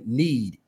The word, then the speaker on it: kneads: When you hear it, and of course me, you know, it sounds like I'm saying need, N-E-E-D. Yep. But strategically, because she's, you know, a strategist, it's, it's spelled K-N-E-A-D-S, like need.